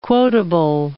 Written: quotable

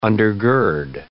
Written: undergird

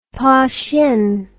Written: Pahsien

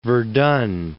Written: Verdun

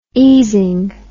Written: easing

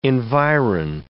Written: environ

